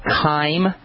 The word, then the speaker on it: chyme: This acid mash is called chyme, and that's spelled C-H-Y-M-E, chyme, and it's going to sit in your small intestine until pancreatic juices, especially sodium bicarbonate, have bathed it and neutralized it.